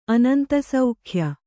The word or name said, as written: ಅನಂತಸೌಖ್ಯ